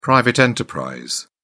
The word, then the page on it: private enterprise: British English